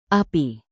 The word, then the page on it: api: indonesian